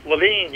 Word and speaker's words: Levine: Do you pronounce your last name Levine or Levin or Levine? – Levine, you got it.